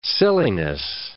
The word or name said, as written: silliness